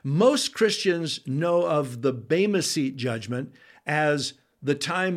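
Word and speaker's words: bema (βῆμα): In fact, when you see the word judgment seat in your English Bible, that is a single Greek word that is pronounced bema.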